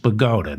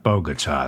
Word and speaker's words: Bogota: And it's pronounced Bogota, not Bogota.